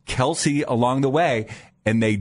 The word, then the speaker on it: Kelce: Because everywhere he'd go, it'd be Kelce, Kelce, Kelce. – Okay. – Even though it's pronounced Kelce, and he just gave up.